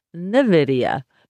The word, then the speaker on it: NVIDIA: That's spelled N-V-I-D-I-A, or you might have heard it called NVIDIA, but it really is NVIDIA.